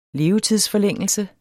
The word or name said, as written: levetidsforlængelse